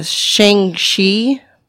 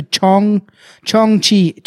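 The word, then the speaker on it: jiangshi: In China, vampires, Shang-Chi. – I looked that up. Same one. It's Chong. It's actually Chong Chi. Yeah, it's weird because it doesn't look like it at all how it's spelled.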